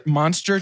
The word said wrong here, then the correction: Munster: It's pronounced Munster. – Munster cheese. – Like Herman.